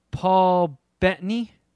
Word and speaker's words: Paul Bettany: And it's like set in the 70s, and it stars Paul Bettany. That's how you say his name, right, Shuddy? The guy that played Vision? – Yes.